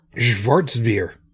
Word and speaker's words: Schwarzbier: Schwarz. Okay, in German, a W is supposed to be pronounced like a V in English, right? – Sometimes. – Schwarzbier.